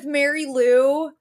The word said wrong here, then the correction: Mari Lou: Well, no, I guess her name's Mari Lou is how you say it, but even the brother that's dating her mispronounces it and calls her Mary Lou.